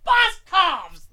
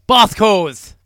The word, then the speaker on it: Boscov's: It's Boscov's. Like, there's a V, a prevalent V. – Well, there's never been in the history of the English language a silent V. – Never. – The V isn't silent.